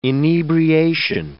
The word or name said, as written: inebriation